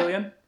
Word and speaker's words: Fillion: Am I saying that right? – Yeah. – Fillion? Yeah. Even Jenna Fischer from The Office is in it.